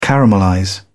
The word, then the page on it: caramelize: British English